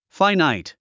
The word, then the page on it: finite: fǽinait; ファイナァイト